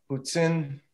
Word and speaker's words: poutine: I learned all about the Poutine.